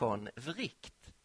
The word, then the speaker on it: von Wright: Den finlandssvenske filosofen Georg Henrik von Wright, ja, hans namn uttalas faktiskt så, Georg Henrik von Wright, han dog för ett par år sedan.